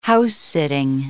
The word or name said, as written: house-sitting